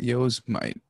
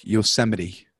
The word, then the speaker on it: Yosemite: It's not Yosemite. It's a national park, Yosemite.